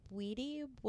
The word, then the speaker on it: Bwiti: I believe it's pronounced Bwiti, Bwiti. I know it's spelled Bwiti, but it is, the discipline that those creatures, are, I guess, that they originate from.